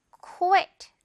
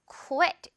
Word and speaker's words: quit: 자, quit. 그렇죠. 그만두다 라는 뜻을 가지고 있고요. Q로 시작하면서 quit 이렇게 발음한다고 했어요. quit, quit, 그만두다. 이건 quiet, quiet이랑 또 완전 다르죠?